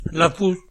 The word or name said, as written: Lafous